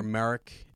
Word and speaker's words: Marek: So Marek. I think it's spelled, Starkiller's name is spelled, so you have Galen and then M-A-R-E-K, Marek. And then you've got this guy Marok, I guess is M-A-R-R-O-K.